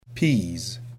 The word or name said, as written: peas